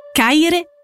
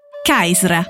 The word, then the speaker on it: Caisra: Dall'etrusco derivano anche molti nomi di città italiane come Tarquinia, che allora suonava Tarkna, e quello della stessa Cerveteri, che si pronunciava Caire, Caisra, per citare solo alcuni esempi.